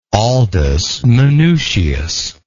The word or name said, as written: aldus manutius